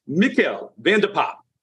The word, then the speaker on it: Michaël van de Poppe: Michaël van de Poppe.